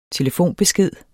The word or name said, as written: TELEFONBESKED